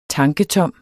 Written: tanketom

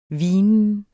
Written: hvinen

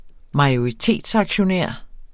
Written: majoritetsaktionær

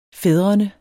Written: fædrene

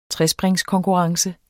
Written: trespring

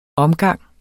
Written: omgang

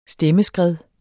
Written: stemmeskred

